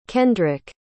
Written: kendrick

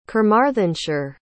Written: carmarthenshire

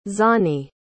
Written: Zani

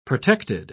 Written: protected

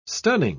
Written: stunning

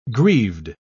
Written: grieved

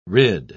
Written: rid

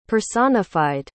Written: personified

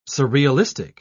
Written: surrealistic

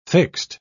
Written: fixed

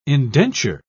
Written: indenture

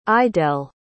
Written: idell

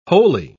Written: wholly

